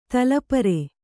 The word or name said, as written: ತಲಪರೆ